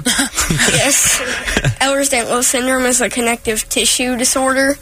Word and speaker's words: Ehlers-Danlos syndrome: Ehlers-Danlos syndrome. – Very good. – Yes. – Did I pronounce it correctly? – You did.